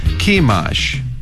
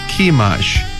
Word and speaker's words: Chemosh: Sihon, S-I-H-O-N, Sihon 이렇게 발음해 주시고 그모스는 C-H-E-M-O-S-H입니다. 그래서 Chemosh, 한 번 더요. Chemosh, 그모스를 Chemosh 이렇게 연습해 주시기 바랍니다.